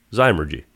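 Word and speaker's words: Zymurgy: Zymergy.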